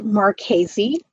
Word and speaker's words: Marchese: Yes, it's M A R C H E S E, and it's pronounced Marchese.